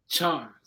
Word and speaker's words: Chawn: Chawn.